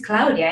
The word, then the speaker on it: Claudia: But then a couple of years ago, I was like, no, my name is Claudia, and I need to own that, right? As I'm owning my voice, I need to own the Italian pronunciation.